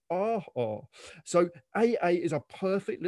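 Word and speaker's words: aa: It's A-A. A Hawaiian word pronounced R-R.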